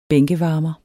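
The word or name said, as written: BÆNKEVARMERE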